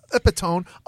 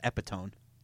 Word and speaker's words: epitome: Because basically, because she's the epitome of whiteness. – Epitome, Joe, not epitone.